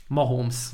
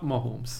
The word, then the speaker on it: Mahomes: Tehát a, lehet, hogy éveken keresztül én magam is rosszul mondtam, meg más szakértők, kollégák is rosszul mondták, de a pronunciation guide-ban, a kiejtési zsargonban Mahomes. Mah- Mahomes. Nem holnap, Mahomes. Mahomes. – És igazából minden kinti kommentátor, kinti szakértő, kinti stúdiós analist Mahomesnak mondja, ő maga is annak mondja, tehát hogy aki azért panaszkodott, hogy én nem azom folyamatosan, az ne panaszkodjon, mert nem úgy kell mondani a nevét.